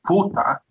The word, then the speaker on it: puta: If you look at the replay in slow motion, he says puta, and then Mason Holgate goes fucking mental. – It's like a softer kind of P, so it looks like you're blowing out rather than pronouncing a P.